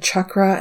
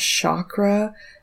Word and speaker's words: chakra: And by the way, I keep saying chakra and not chakra because that is how I have recently learned that that is the correct pronunciation.